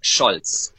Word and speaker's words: Scholz: Just while you're there, somebody wants me to pronounce Scores correctly. Can you help me with that pronunciation? – It would be Olaf Scholz.